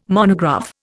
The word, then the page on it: monograph: Indian